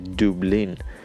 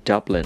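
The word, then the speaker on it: Dublin: دوبلین یاد میشه دوبلین این در فقط میخوام اشاره بکنم که به انگلیسی اگر ما بخوایم تلفظ بکنیم این دابلن هست دابلن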